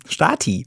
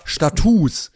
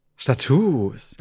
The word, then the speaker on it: Status: Stati. – Hätte ich nämlich auch gedacht. Nach lateinischer Deklination müsste es eigentlich Stati sein. Es ist aber Status. Es wird genauso geschrieben, aber mit langem U ausgesprochen. – Status.